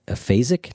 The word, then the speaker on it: aphasic: And you tell a story about a man who, he's an aphasic. Did I say that right? – Yeah.